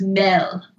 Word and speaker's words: meget: M-E-G-E-T is pronounced mel. – What? – And M-A-D is mal.